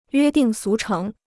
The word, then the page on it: 约定俗成: yuē dìng sú chéng